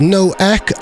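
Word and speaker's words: Nueq: You found that idea preposterous and suggested that it was pronounced Nuke because it's N-U-E-Q. But it's not, it's No Ek, and it's actually named after the Mayan pronunciation of Venus.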